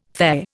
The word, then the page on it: Fe: Indian